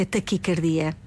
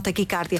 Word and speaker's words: taquicardia: O A é fechado, a forma correta, a pronúncia correta é taquicardia. E não taquicárdia.